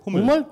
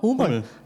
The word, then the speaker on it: Hummel: Philipp, how do you say that of Deutsch? Hummel? – Hummel. – Hummel. Okay, it's Hummel. It's bumblebee, the German word for bumblebee.